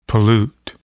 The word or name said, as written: pollute